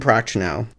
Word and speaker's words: Prochnow: Uh, Jurgen Prochnow.